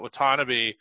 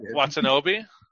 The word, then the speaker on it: Watanabe: And I think what like I guess uh, Watanabe is um, I think I butchered that. – Yeah, uh, Watanabe.